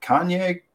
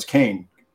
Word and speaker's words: Kane: First and name is it Kanye or no? It's Kane. Excuse me, Kane. Kane. It's spelled weird.